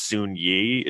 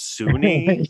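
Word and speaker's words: Soon-Yi: First of all, the biggest revelation is that it's not pronounced Soon Yi, it's Sunni.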